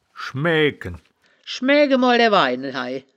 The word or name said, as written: schmaeaegken